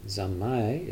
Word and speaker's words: zanmai: But zamai is the Japanese pronunciation of samadhi.